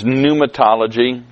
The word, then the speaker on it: pneumatology: and the term is pneumatology. There's a P at the beginning of it, but we don't pronounce it.